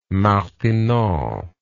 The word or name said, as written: martinon